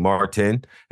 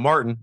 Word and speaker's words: Martin: But I think, honestly, if I had to point it at anything, it stems from my name being Martin and having two syllables and everybody hearing Mark when I say it.